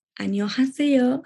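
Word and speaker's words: Annyeonghaseyo (안녕하세요): No, ahora yo digo Annyeonghaseyo.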